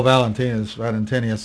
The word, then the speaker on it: Valentinus: How about Valentinus? I don't recognize him. – Oh, Valentinius, sometimes it's pronounced.